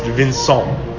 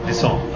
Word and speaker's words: Vincent: It's pronounced Vincent. – Vincent. Okay, en français.